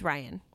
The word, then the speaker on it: Rian: It's Ryan. – Well, he spells it weird. – He does spell it weird. As someone who's seen him talk at like Comic-Con and stuff, he pronounced it Ryan.